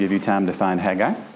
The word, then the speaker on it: Haggai: After 6 weeks, hopefully we've cut the time to find Haggai down from 10 minutes to 5 minutes. That would be a good thing. So how we pronounce the word, it's actually Haggai, it's not Haggai.